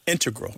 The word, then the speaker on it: integral: Yeah, but both correct, just to clarify here, both correct, but the mathematicians and math-using scientists are more likely to put, say, integral with the stress on the first syllable.